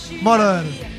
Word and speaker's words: Moroder: Moroder.